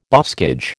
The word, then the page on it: boscage: American English